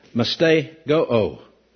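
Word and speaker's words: mastigoō: It's M, this thing wants to skip with me a little bit if I don't take time, M-A-S. T-I-O-O. That's pronounced mastego-o. Mastego-o. And it means intense suffering. Intense suffering. M-A-S-T-I-O-O.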